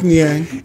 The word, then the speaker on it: Niang: James Harden, PJ Tucker, and how do you pronounce it? Niang? – Mm-hmm. George Niang.